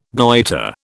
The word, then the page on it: goitre: British English